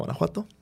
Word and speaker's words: Guanajuato: Se la llama Guanajuato. Sí, lo dije bien.